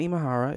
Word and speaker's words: Imahara: Well, Grant Imahara, if I'm pronouncing that name correctly.